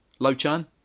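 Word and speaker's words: Lau Chan: Then we've got, I'm going to say his name again wrong, aren't I? Lao Chan. – Yes! – First time I've said it right.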